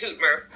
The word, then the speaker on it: Hootemer: It's Hootemer, but you say it like everybody else does.